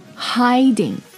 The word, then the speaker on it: hiding: So, okay, hiding. H-I-D-I-N-G Heading H-E-A-D-I-N-G 所以它里面其实这两个单词之间发音的不同是在元音的不同上面。